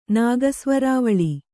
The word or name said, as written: ನಾಗಸ್ವರಾವಳಿ